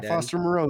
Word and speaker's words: Foster Moreau: Foster Moreau, the tight end.